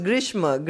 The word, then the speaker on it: Grishma: It's Grishma. Grishma means the summer season. – Yeah. – Yes. Mm-hmm. So it's not really Grisham, it's Grishma.